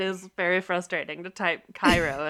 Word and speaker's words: Cairo: Want to move on to Ethan and Olivia's house in Cairo, which I would just like to say, Cairo, Georgia, is spelled Cairo like Egypt, but you say it like the sweet syrup.